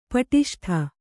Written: ಪಟಿಷ್ಠ

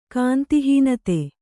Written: ಕಾಂತಿಹೀನತೆ

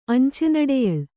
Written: ಅಂಚೆನಡೆಯಳ್

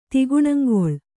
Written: ತಿಗುಣಂಗೊಳ್

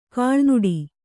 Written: ಕೀಳುನುಡಿ

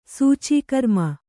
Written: ಸೂಚೀಕರ್ಮ

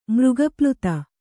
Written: ಮೃಗಪ್ಲುತ

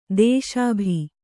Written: ದೇಶಾಭಿ

